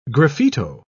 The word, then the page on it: graffito: British English